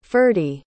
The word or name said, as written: ferdi